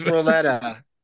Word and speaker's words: Loleta: And it's Lolita, isn't it? – You keep calling it that because I think it's Loletta. – Yeah, it's spelled Loleta.